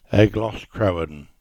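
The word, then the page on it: Crowan: Cornish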